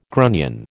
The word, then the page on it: grunion: American English